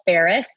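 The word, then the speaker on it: Faris: And you can find me on Instagram or Twitter at Paula Ferris. And my last name is spelled just like the city of Paris, but with an F, like Frank. So it's not F-A-R-R or F-E-R-R, it's F-A-R-I-S.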